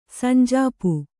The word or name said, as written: ಸಂಜಾಪು